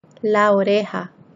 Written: la oreja

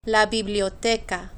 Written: la biblioteca

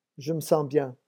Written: je me sens

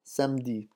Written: samedi

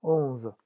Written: onze

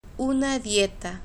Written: una dieta

